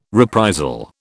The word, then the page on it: reprisal: British English